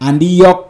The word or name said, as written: Andiyawk